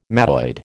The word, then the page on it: mattoid: American English